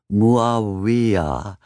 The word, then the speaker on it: Muawiyah: The slinky sinuousness of the 4 drawn-out syllables of the name, Muawiyah, seems almost tailor-made for the sheer curses that would be heaped on it in centuries to come.